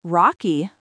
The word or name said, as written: rocky